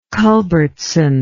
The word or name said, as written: culbertson